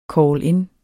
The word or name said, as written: call-in